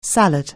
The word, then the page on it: salad: anglais